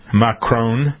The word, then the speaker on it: Macron: That's the way to pronounce it. Macron.